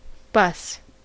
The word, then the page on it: bus: American English